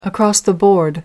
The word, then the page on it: across the board: American English